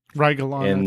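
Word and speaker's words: Rygelon: I haven't played it, so I don't know how to pronounce the name. Rigelin? – Rigelon, I think is how they say Rigelon.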